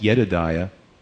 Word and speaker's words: Jedidiah: And actually, it's pronounced Jedidiah, beloved by the Lord.